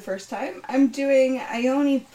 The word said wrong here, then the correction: Iolani: I already said it wrong. Iolani Palace in Oahu, Hawaii, which is, if not thought of as haunted, is thought of as a spiritual place.